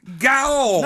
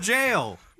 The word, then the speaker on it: gaol: Yeah, I knew it was a prison, but I thought it was pronounced gaol. – No, it's pronounced jail.